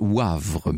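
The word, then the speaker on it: Woëvre: Laurence me fait amicalement observer que dans la Meuse, la Veuvre, ça se prononce Wavre, me dit-elle, sur place.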